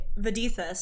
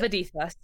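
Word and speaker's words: Vadithas: Okay, Vidithus. So it's V-A-D-I-T-H-A-S. – Gotcha. – Vidithus.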